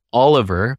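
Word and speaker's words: OL.VER: And the artist, yeah, and the artist is Oliver, but it's spelled as O L dot V E R.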